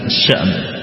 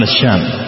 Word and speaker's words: الشأم: والشام تنطق على المشهور بالهمز، الشأم بالهمز، تنطق على المشهور بالهمز، الشأم بسكون الهمز، بسكون الهمز الشأم، وتنطق أيضا بتحريك بفتح الهمز الشأم، الشأم بتحريك الهمز، وتنطق أيضا بتسكين الهمز كنهر ونهر، شام وشأن كنهر ونهر. وتنطق أيضا بحذف الهمز فيقال الشام، يقال الشام بحذف الهمز. والأشهر هو ذكر الهمز،